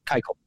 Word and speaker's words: Keuchel: I mean, Dallas, uh, uh, what is it? Kluchel? Is it Kluchel? Keuchel. Keuchel. Dallas Keuchel.